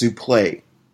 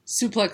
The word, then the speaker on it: suplex: That's sometimes he's like, sometimes just how you say it, you know, or how, you know, when they say the term suplex for wrestling? – Uh-huh. – It's actually pronounced souplet. – Because it's French? – I guess, yes. And that's how it's been said by one of the most famous announcers, Gordon Solie.